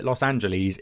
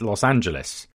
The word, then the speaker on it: Los Angeles: He says, never call it Los Angeles, it's Los Angeles.